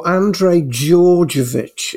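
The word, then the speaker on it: Andrej Djordjevic: Oh, Andrej Jojovic. It's a silent D.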